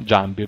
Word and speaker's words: Giambi: Isringhausen e Giambi. Mi chiedo scusa per la pronuncia in inglese, mi è venuta automatica. Giambi, ovviamente, perché è un paesà.